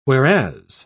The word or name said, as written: whereas